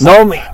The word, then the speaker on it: Noem: It's Nomi. Nomi. Name her. Say her name. – Oh, Kristi Noem, yeah.